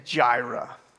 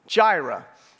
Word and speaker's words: Jireh: The name of the series is actually a name for God in the scriptures, and the way you say it is Jireh, Jireh.